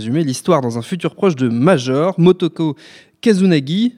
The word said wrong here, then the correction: Kusanagi: je le dis bien Daniel? Pardon. – Kusanagi. – C'est ce que j'ai écrit en plus. Tu sais que c'est ce que j'ai écrit? Ouais. J'ai dit Kazunagi, mais c'est Kusanagi qui a sur marqué.